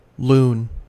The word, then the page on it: loon: American English